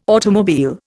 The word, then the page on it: automobile: Indian